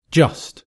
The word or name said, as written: just